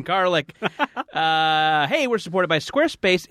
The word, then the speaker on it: Squarespace: We also have another supporter this week, our friends at Squarespace. Is that how it's pronounced? – Yes, you've pronounced it perfectly.